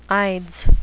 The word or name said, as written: ides